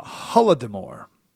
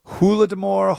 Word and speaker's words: Holodomor: The uh, Holodomor, Holodomor, Holodomor, Holodomor, different pronunciations.